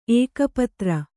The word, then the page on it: ಏಕಪತ್ರ: Kannada